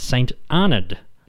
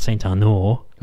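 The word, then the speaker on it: St Arnaud: And James did assure me during the interview that the town is pronounced St. Arnold, not St. Arnold, not St. Arnaud, like Chanel number 5. But St. Arnold, that's the Australian way of saying it.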